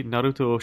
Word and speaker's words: Naruto: But I've been watching Naruto. – Yes. – Or, you know, the real way to pronounce it, Naruto.